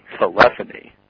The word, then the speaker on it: telephony: TELEPHONY. – Telephony. – Very good. It's fucking telephony. Not telephony, not telephony. It's telephony. Okay? Everybody say it with me. – Telephony. – Telephony.